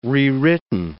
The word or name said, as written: rewritten